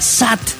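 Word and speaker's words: sat (七): No, algo muy, muy cercano, porque siete en chino, el carácter chino se pronuncia sat en cantonés.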